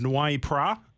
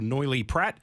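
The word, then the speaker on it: Noilly Prat: is to buy a bottle of Noy Prat as a vermouth, or Noily Prat if you don't know how to say it, and that's cool, and get a bottle of MGC gin.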